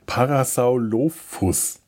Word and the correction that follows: Parasaurolophus: Nein, Parasau, Parasaurolophus.